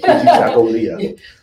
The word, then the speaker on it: Kujichagulia: But Kujichagulia. – Yeah, Kujichagulia. Kujichagulia.